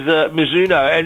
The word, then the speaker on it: Mizuno: This is Mizuno.